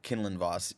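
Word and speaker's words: Quinlan Vos: The name drop of Sage, you'll probably know how to actually say his name, is Quinlan Voss. – I think it's Kinlan Voss. – Kinlan Voss, yeah.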